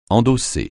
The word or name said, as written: endosser